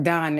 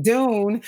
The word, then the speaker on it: Dun: I know you call the security guard Dunn, as probably do many actors around the country. It's actually pronounced Dune, but I'll tell you why. It's Dune, D-U-N, which looks like Dunn. But it's Dune because I just named him in my own little heart.